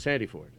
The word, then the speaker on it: Sandiford: Chris Sandiford.